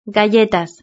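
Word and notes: galletas: Spanish (Spain)

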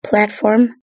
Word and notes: platform: British English

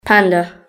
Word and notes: panda: British English

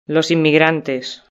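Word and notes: los inmigrantes: Spanish (Spain)

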